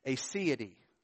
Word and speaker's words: aseity: It's aseity. Aseity. Aseity is spelled A-S-E-I-T-Y.